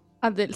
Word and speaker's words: Adele: Adele, sí, es lo único que hemos pronunciado bien en esto, déjame contarte.